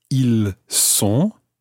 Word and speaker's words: ils sont: But compare it to être. Ils sont. – Ils sont. – So that time it's an S sound, a S. Ils sont. – Ils sont. – So in that situation, you're not pronouncing the S of the il, but you're pronouncing the S of the word sont, S-O-N-T.